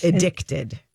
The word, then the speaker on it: Edikted: It's spelled, it looks like edited with a K in the middle. – Yeah. – It's EDIKTED, but it's Addicted.